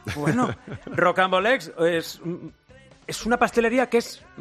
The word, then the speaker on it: Rocambolesque: Directora de Rocambolesque.